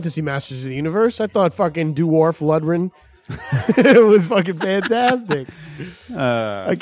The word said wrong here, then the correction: Dolph Lundgren: What's how do you say it? Say it one more time. – Dolph. Lundgren.